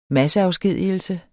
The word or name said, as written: masseafskedigelse